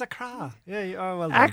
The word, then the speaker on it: Accra: Okay, the cheapest thing I could find was Aer Lingus and Royal Air Morocco for €700 to Accra. Accra is the capital of Ghana. But there's a few other things. – I would have thought it was Accra now. – No, Accra.